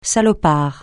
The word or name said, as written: salopard